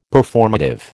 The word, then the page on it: Performative: American English